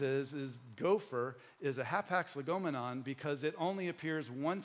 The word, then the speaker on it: hapax legomenon: But a hapax legomenon, which I'm saying that right because I had Google say it to me 15 times. So I am saying it right.